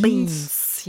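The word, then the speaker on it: Beinz: Beans, yes. Also Beans, Bohnen. Beins geschrieben, Beins geschrieben. – Okay. – Beans, ja, das ist ausgesprochen.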